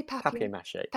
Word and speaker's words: papier-mâché: For which they need these papier-mâché? Am I saying that right? – I'd say papier-mâché.